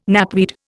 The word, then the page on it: knapweed: Indian